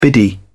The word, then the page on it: biddy: British English